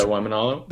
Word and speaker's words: Waimanalo: and I've been swimming out at Waimanalo. I'm saying it wrong, but that's Waimanalo.